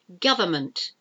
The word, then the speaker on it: government: Government. Government. It's government. You don't pronounce the middle N. Government. And solemn. S-O-L-E-M-N.